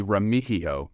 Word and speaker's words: Remigio: Yeah, it's actually Remigio. The G is a very soft, silent G. There you go, Remigio.